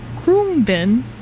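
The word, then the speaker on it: Khruangbin: That was So We Don't Forget by Krumbin, which we looked up the pronunciation of because I never knew.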